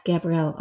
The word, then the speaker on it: Gabrielle: You can also follow me on Twitter to get updates about new podcasts and more, at Gabrielle Author.